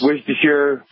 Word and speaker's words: Worcestershire: Bloody Mary, it's tomato juice, horseradish, Worcestershire, however you want to pronounce it. It's the worst Chestershire sauce. – Worcestershire. – Yeah, Worcestershire sauce.